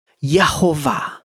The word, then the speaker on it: Jehovah: But Jehovah, the Hebrew pronunciation would be Yehovah, right? So Yehovah.